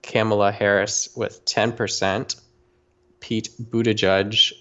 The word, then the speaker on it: Buttigieg: Yeah, P Pete Buttigieg. It's spelled Buttigieg. Gieg, Buttigieg.